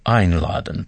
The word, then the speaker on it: einladen: Бывают приставки, которые придают глаголу иную специфику или даже полностью меняют его значение – Послушайте еще раз глагол «einladen» – «приглашать». Обратите внимание на приставку «ein». На нее падает ударение, потому что значение ее велико. Einladen.